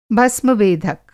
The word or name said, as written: भस्मवेधक